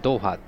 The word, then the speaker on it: 豆花: ドーファーって知ってる豆の花って書いてドーファーって読むんだけどこれ中国読みだけど日本でも同じ読み方してる